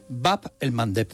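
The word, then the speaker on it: Bab el-Mandeb: Bab el Mandeb. – Bab el Mandeb, esa me la voy a apuntar. – Cuesta un poquito pronunciarlo, por Mandeb. Eso en Occidente se dice el Mandeb.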